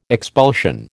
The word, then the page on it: expulsion: American English